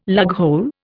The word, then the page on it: lughole: Indian